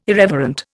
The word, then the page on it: irreverent: Indian